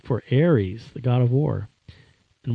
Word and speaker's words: Eretz: That Ares Israel was spelled E-R-E-T-Z, but it's pronounced Ares. That's actual Hebrew.